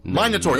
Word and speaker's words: Minotaur: Minotaur.